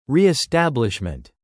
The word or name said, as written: reestablishment